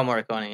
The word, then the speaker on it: Morricone: Ennio, I don't know how to say his name, Ennio Morricone. – Oh, Morricone, yeah.